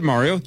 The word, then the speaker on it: Mario: You said Mario. You said it correctly.